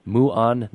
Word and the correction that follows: Maan: News Network? – The Maan News Network. – The Maan News Network.